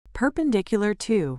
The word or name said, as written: perpendicular to